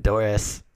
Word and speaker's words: Dorrace: Um, but Dorrace spelled like Horace. It's like it's D O R R A C E which mm-hmm.